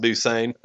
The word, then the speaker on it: Busan: it's pronounced Busan.